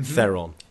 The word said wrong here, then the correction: Theron: No. It's pronounced Thron. – Thron. – She has said this. It's the South African.